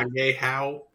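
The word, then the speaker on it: Ahegao: And then like seeing some more of the, I don't know how you say it, Ahagyo. – It's Agehao. So yeah. Agehao.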